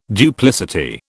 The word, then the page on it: duplicity: British English